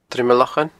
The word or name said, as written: Druim a Lochain